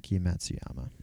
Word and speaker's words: Hideki Matsuyama: I'm gonna Hideki Matsuyama. – Hideki Matsuyama.